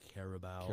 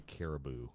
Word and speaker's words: Carabao: I'm impressed you said it right. – Carabao. Carabao. Wow. Uh, yeah, it's it's a delicious drink. Um, no sponsorship. – I'm from southeastern Ohio. I'd say caribou.